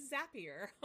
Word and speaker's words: Zapier: And I was like, how do you say it? Zapier? Zapier? And she goes, you'll be happier with Zapier.